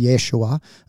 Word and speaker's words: Yeshua: Uh, Joshua, uh, Hebrew, pronounced Yeshua, um, right?